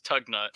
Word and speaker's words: Tugnutt: So Ron Tugnutt, when he was on the Quebec Nordiques, yes, Tugnutt. – that's a name. – Yes, that was not a mispronunciation.